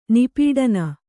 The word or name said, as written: ನಿಪೀಡನ